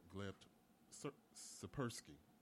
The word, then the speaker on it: Gleb Tsipursky: Well, our second guest is online, Mr. Gleb Tsipursky.